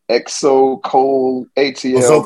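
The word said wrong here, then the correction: Xocolatl: Zocalai. – Yeah, what he just said.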